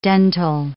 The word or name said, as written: dentil